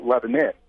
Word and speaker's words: Lebanon: Most of them actually would tell me, well, you pronounce it Lebanon, we pronounce it Lebanon. So there's a bit of a difference in the pronunciation.